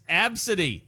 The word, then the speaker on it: Abcde: All right, I want you to tell me how to pronounce this. It's spelled A-B-C-D-E. – A-B-C-D-E? – Mm-hmm. That's how you spell their name. – Absid. Absidy. – You're close. Yes, Absidy. Yes, oh, it's Absidy? – Yes, you were right, though.